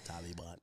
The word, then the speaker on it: Taliban: Taliban. – Am I saying it right? – Taliban. You're saying it like so a yard man. No, Taliban.